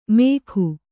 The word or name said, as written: ಮೇಕು